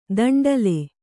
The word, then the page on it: ದಂಡ್ಲೆ: daṇḍle